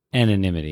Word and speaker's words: anonymity: The whole idea is that comedy is designed like, I always say it wrong. Anonymity. – Anonymity, yeah.